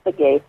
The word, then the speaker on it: Begay: Thomas Begay or Begay, I'm not sure how to pronounce it. Oh, yeah, it's Thomas Begay.